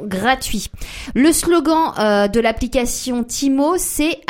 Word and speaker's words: Temu: Timo. Qui s'écrit Temu mais qu'on prononce Timo parce que c'est une application chinoise.